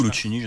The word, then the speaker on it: Luchini: Comment on le prononce, Fabrice? – Luchini ou Luchini, je sais pas. – Je pense que c'est Luchini.